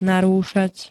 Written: narúšať

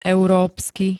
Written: európsky

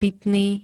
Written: pitny

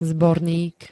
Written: zbornik

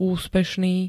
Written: úspešný

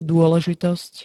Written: dôležitosť

